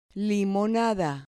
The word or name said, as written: limonada